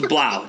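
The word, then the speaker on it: Blough: But, you know, so it's Blau. It's David Blau. That's how you say it.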